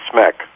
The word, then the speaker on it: Smek: So you've got a movie out right now called Home, and you voice the character of Captain Smek. Am I pronouncing that right? – That's right. That's right. You speak with the accent very, very well. It's Captain Smek.